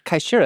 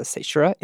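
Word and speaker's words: caesura: Well, kaishura, as it should be pronounced, but it's sometimes a seishura, is a pause.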